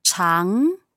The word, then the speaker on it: 常: Chang. – Chang, is that the one that goes down and then up? – No, chang, second tone. – Just going up. Just going up. – Mm-hmm. – Chang.